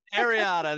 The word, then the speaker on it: areata: So you want to talk about the alopecia areata, the, am I saying that right? Areata? Is it? – Ariana. – Areata. There you go.